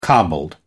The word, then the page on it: cobbled: British English